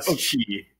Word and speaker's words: Chiy: You were close enough. Chiy. So Chiy. Yep.